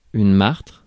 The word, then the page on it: martre: [maʁtʁ]; France